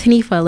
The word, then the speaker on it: Hanifa: It's like my name's Hanifa, like spelled H-A-N-I-F-A.